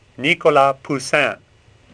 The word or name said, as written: Poussin